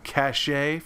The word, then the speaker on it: cachet: Navigation, cash, or I'm sorry, cachet for those who say it like that. I've said cash my entire life.